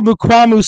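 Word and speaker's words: Mukuamu: Horn, I can't pronounce the other corner's name, Malafau, is that how you say it? – Israel Mukwamu, sir.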